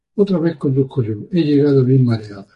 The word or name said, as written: mareada